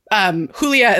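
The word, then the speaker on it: Julia: Mine was Hoolia, H-O-O-L-E-E-U-H, which is Hoolia is how you say my name in Spanish. – Right. – And so my friends all used to call me Who, H-O-O.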